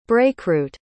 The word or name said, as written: brakeroot